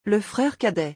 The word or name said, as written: Le frere cadet